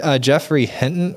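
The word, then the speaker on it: Geoffrey Hinton: You mentioned Jeffrey Hinton earlier.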